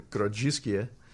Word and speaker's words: Grodziskie: It's a style of beer that was very popular in Poland called the Grodziskie that kind of died out.